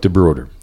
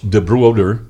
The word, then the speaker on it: DeBroder: Chuck DeBroder. And it actually is Dutch, DeBroder.